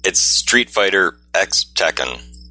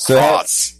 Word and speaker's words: Street Fighter X Tekken: It's Street Fighter X Tekken. – Yeah, it is. – It's not cross.